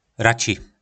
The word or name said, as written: raci